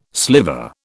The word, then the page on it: sliver: British English